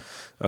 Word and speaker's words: Jidenna: How do you pronounce Jidenna. – It? Jidenna.